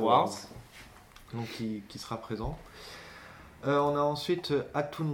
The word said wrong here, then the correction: Autumn: Reezer, donc je ne sais pas si je prononce correctement. – C'est Autumn.